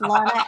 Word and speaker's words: Lahna: I always say that Lana is spelled with an H, L-A-H-N-A. Otherwise, backwards, it would spell anal. So it's just Lana, L-A-H-N-A, Turner, T-U-R-N-E-R.